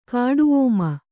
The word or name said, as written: ಕಾಡುಓಮ